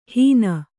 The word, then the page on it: ಹೀನ: Kannada